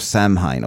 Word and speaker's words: Samhain: And the rituals of Samhain, or Samhain, let's call it, a friend. Apparently H is silent in that.